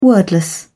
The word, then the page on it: wordless: British English